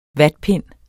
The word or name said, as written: vatpind